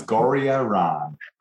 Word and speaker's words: Gorriaran: It's pretty good, Dawn. It's Gorriaran.